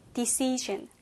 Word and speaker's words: decision: decisions에서 강세가 이음절에 있어서요. 그래서, decision이 아니고, decision. decision 이어서 바로 이 중간 부분 있죠? 이 이음절에 조금 더 강한 억양, 강한 그런 강세를 둬야 합니다.